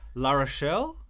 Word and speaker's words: La Rochelle: Yes, but news just comes through that he's going to La Rochelle. Is that how you pronounce it? – That's correct.